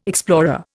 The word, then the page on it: explorer: Indian